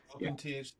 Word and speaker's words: OpenTHC: Yeah, OpenTHC.